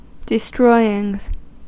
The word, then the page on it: destroying: /dɪ.ˈstrɔɪ.ɪŋ/